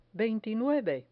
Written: veintinueve

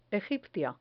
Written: egipcia

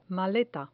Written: maleta

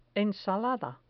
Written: ensalada

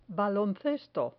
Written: baloncesto

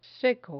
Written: seco

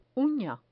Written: una

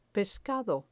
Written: pescado

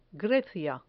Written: grecia

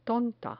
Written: tonta

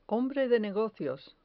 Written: hombre de negocios

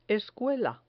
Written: escuela

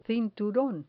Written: cinturon

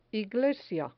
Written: iglesia

